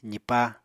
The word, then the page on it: zipá: apatani